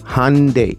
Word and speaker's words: Hyundai: Me gusta la pronunciación, ¿eh? A ver, vamos a ver otra vez. Hyundai. Ok, perfecto.